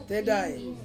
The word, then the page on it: tedai: Ansus